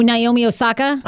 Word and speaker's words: Naomi Osaka: Oh, Naomi Osaka.